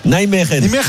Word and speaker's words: Nijmegen: Non, enfin, je vous le ferai pas prononcer en néerlandais parce que vous avez des problèmes de prononciation. – Ah, comment on dit ? – Nijmegen. – Nijmegen.